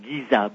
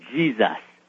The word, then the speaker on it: Giza: Wallace Budge, a curator for the British Museum, said should be pronounced not Giza, but Jesus.